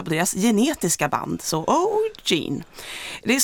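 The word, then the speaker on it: O'G'3NE: Eh, O'Gene uttalas det trots att det är en siffra där i mitten, är en grupp bestående av tre systrar varav ett tvillingpar.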